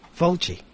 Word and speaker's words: Fulci: V-O-L-C... – Fulci. F-U-L-C-I. – Oh, okay. Although it's like I'm sorry. – It's all good. – I was distracted there for a second. Fulci.